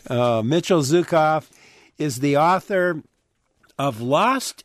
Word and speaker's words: Zuckoff: And his last name is spelled, by the way, it's pronounced Zukov, but it's spelled Z-U-C-K-O-F-F.